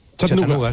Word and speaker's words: Chattanooga: ¿Se pronuncia así en Chattanooga? – Chattanooga, sí.